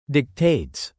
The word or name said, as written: dictates